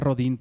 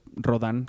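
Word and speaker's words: Rodin: Pero que una que se llama, por cierto, Matar a Rodán, que yo le decía Rodín porque se escribe Rodín, pero es Rodán, que es creo Rodán.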